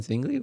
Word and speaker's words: Zwingli: So Zwingli. – Isn't he German? Or Austrian, or what is? Where is he from? – Oh, I forgot. Oh, sorry. – I thought you pronounced the W as a V.